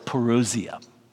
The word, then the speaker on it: parousia: but I would say the word is parousia, okay? Parousia, P-A-R-O-U-S-I-A in English. It's not the Greek spell, but that's the English equivalent. That word parousia, the coming, is the most common word in the New Testament for the coming, the second coming of Jesus.